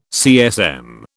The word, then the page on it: CSM: British English